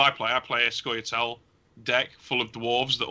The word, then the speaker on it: Scoia'tael: Have I pronounced Scoia'tael. That right? Scoia'tael.